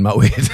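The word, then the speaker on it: Moët: When it's like you actually realise that Moet is the correct way to say it, like poet.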